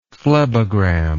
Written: phlebogram

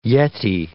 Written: yeti

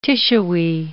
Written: tissuey

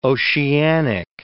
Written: oceanic

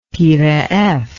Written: Peiraievs

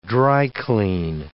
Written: dry-clean

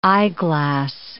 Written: eyeglass

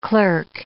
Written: clerk

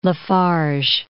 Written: La Farge

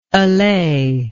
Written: allayer

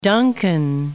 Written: Duncan

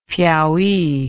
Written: piaui

